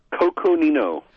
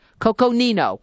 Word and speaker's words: Coconino: So I need to know, pronounce the name of your company, which evidently is also your county. – Coconino. – Coconino. – Yes, okay.